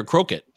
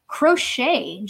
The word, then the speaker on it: Crochet: Is it Crochet or Croquet? – Crochet, just like the art. So there's knitting and there's also crochet. – There is. Yes, my grandma knew something about that. Garrett Crochet, can he?